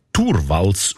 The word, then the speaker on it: Torvalds: Der Linus Torvalds, eigentlich Turwals ausgesprochen.